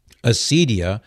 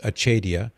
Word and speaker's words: acedia: Acedia, sometimes pronounced achedia, or spiritual sloth, goes so far as to refuse the joy that comes from God and to be repelled by divine goodness.